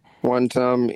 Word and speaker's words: wantam: When you say Wantam, is it in Swahili or Wantam? It's like, you know, like that's just the way you pronounce it in a Kenyan accent, like mm-hmm. one term. – Wantam.